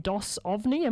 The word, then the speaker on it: Dos Ovni: playing with Giantess and Dos Ovni. Am I saying that right? I'm not entirely sure. – Yes, yep, you are.